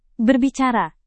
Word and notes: berbicara: indonesian

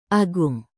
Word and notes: agung: indonesian

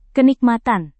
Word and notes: kenikmatan: indonesian

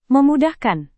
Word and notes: memudahkan: Indonesian

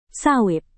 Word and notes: sawit: indonesian

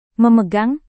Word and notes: memegang: Indonesian

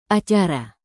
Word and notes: acara: indonesian

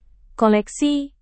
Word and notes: koleksi: Indonesian

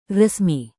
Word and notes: resmi: indonesian

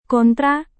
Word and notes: kontra: indonesian

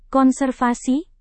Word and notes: konservasi: indonesian

